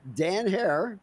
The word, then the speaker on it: Herer: Dan Hare. Did I pronounce that correctly? – Yes, sir.